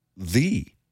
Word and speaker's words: ye: But it's actually pronounced thee. – Huh. – In medieval English, people used a Y to abbreviate TH.